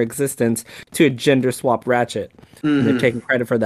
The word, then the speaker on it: Ratchette: Also, it is spelled R-A-T-C-H-E-T-T-E. How y'all pronounce that? – Ratchet. – Ratchet, yeah.